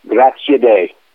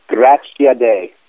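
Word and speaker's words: Graziadei: How do you say your last name? – Grazie Day. – Say it again? – Grazie Day. – Grazie Day? – Yep. It means thanks to God.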